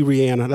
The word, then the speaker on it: Rihanna: Now, so Rihanna, and that's actually Rihanna. That's how you're supposed to pronounce it.